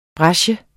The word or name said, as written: brecher